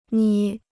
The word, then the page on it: 捏: niē